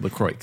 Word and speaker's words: LaCroix: No, it's really said LaCroix. – No, it's not. – Yes. – Well, no one ever says that. – I know. No one ever says it. But Landon, was telling me that he was talking to people who... – Landon, a human. – ...advertise for the brand and it's pronounced LaCroix.